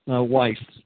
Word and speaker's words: Weiss: Am I saying your name right? – Weiss. That's all right. – Weiss. Sorry about that.